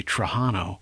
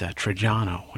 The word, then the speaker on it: Trajano: And I was just talking to Zach as well, asking him about the pronunciation of his name, and it's not in the Spanish norm, which would be Trajano. It's Trajano, which is Italian.